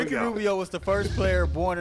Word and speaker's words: Rubio: She's like, is that a Rubio? I said, stop rolling your tongue when you say his name around me like that. – Yeah. – It's Rubio, not Rubio.